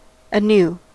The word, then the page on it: anew: American English